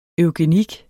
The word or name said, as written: EUGENIK